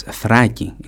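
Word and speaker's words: Thraki (Θράκη): Thrace, the Paeonians, and it's not, I don't know if you should even pronounce it Thrace, it's Thraki in Greek. Maybe the person writing Thrace in English the first time wants to say Thraki.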